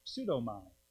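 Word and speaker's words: Pseudomai (ψεύδομαι): Pseudomai. In Greek, we pronounce the P, so pseudo, we have that word in English, we know what that means, false.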